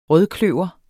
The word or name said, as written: rødkløver